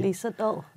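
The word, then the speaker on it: Lee Sedol: And then we have, in 2015, a computer defeats Go expert Han Fuei, and the following year it defeats Go grandmaster Li Sedol. I'm not at all sure I'm pronouncing that correctly. – Li Sedol.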